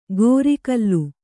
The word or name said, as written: ಗೋರಿಕಲ್ಲು